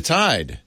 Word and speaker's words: Vermintide: Vermintide. So it's Vermintide, dude.